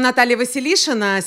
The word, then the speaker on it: Natalia Vasiliscina: Mi presento io, mi chiamo Natalia Vasiliscina, si pronuncia così. Ma chiedo di chiamarmi la Nata perché è più facile per voi, meno stressante per me. Una volta un italiano ha provato di pronunciarmi un nome. No, non è morto, semplicemente non è riuscito, mi ha chiamato Natalina Vasilina. A quel punto ho pensato di iniziare a fare la carriera di pornstar.